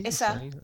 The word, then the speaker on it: Asa: Une série anglaise, donc avec alors je sais pas comment on prononce, on dit Asa ou Ace ou Asa Asa Butterfield.